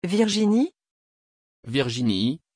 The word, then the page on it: virginie: French